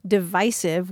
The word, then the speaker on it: divisive: And it's interesting for what it's worth to look at the American Heritage's usage panel, because in 2013, divisive was the preferred pronunciation of 88% of their usage panel, which is made up of people of letters. And back in 2001, the short I, rhyming with permissive, was acceptable to only 16% of the panel, but by 2013, it was deemed acceptable by 65% of the panel.